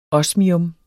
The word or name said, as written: osmium